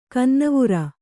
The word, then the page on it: ಕನ್ನವುರ: kannavura